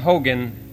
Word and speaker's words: Haugen: And we pronounce his name, H-A-U-G-E-N, Hogen, in English.